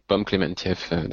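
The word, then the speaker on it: Pom Klementieff: Pomme Clément Thiès. – Ouais, Pomme Clémentieff.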